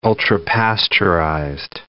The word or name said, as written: ultra-pasteurized